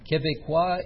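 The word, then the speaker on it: Québécois: Quebecois is the right pronunciation of Quebecers.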